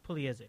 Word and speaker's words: Pugliese: How do you pronounce that name? – Pugliese.